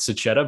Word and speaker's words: Sachetta: I kind of primed you in the wrong direction. It was one of those, don't say it this way, say it this way. So it's Cicada, but no hard feelings.